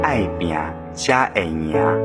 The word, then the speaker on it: 爱拼才会赢: 依句惯例，我们来学习一句长句：“ 爱拼才会赢”，读作“ 爱拼加会赢”。爱拼才会赢，读作“ 爱拼加会赢”。